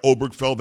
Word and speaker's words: Obergefell: He can't pronounce Obergefell. Obergefell. I mean, can you say you can say that right? Obergefell.